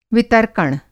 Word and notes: वितर्कण: Hindi

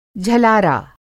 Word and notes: झलारा: Hindi

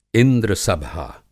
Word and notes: इंद्रसभा: Hindi

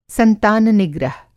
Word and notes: संतान निग्रह: संतान-निग्रह

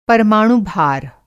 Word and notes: परमाणु भार: Hindi

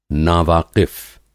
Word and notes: नावाक़िफ़: नावाकिफ़

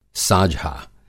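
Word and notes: साझा: Urdu script ساجھا

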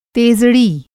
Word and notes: तेज़ड़ी: Hindi

